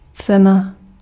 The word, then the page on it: cena: [tsɛna]; /ˈt͡sɛ.na/